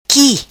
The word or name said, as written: qui